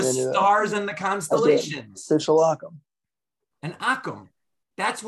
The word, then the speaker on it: akum: It's called an akum. Akum. In English, it's spelled A-K-U-M, but it's really an acronym.